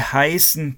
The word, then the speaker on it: heißen: Или А, Sie heißen Пётр. Heißen. Пишется H-E-I Scharfes S E-N. Комбинация «ei» – это для звука «ай».